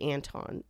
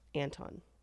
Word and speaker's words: Anthon: But Martin Harris takes the paper with the characters to a man named Charles It's spelled Anthon, but I think it's actually Anton is how it's pronounced. So Charles Anton. Anton.